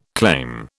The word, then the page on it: claim: British English